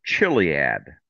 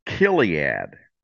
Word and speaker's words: Chiliad: When you look at it, you would probably pronounce it Chiliad. But it's really not pronounced that way. And by the way, the category is Crimes Against Humanity, The Great Awakening. It's Right. – actually pronounced Kiliad. So the CH is a hard K. Kiliad.